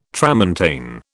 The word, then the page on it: tramontane: British English